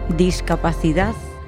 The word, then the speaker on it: discapacidad: Keep an ear out for the accent from Spain, where the C, which usually sounds like an S sound, is pronounced with a th sound. So, discapacidad sounds like discapacidad.